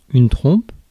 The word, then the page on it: trump: /tɹʌmp/